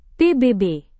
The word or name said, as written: pbb